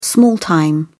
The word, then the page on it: small-time: British English